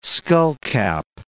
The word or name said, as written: skullcap